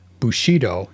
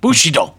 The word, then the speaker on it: Bushido: And I've been told that the accent for Bushido is on the first syllable, not the second. – Of course. I always say it that way. Bushido.